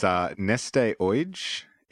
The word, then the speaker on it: Neste Oyj: You're going to test my pronunciation here, but it's Neste Oyj, N-E-S-T-E-O-Y-J, a Finnish petroleum refinery that came in with a score of 83% out of 100.